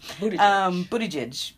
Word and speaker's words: Buttigieg: My name is Pete Buttigieg, Mayor Pete, Buttigieg.